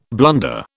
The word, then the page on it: blunder: British English